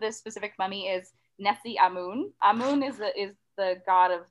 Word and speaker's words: Amun: It's spelled A-M-U-N, but it's the way that I heard it pronounced in a couple different documentaries that I watched clips of to try to get the pronunciation right.